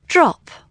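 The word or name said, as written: drop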